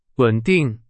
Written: 稳定

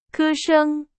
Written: 歌声

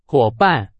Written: 伙伴

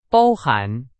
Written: 包含

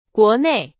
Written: 国内

